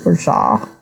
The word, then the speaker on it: orgeat: Lemon twists, orgeat syrup, which I've always wondered about how to pronounce that, but... – Mm-hmm. – I'll follow Alex. He says Urshaw, I think. – He also says Urshaw.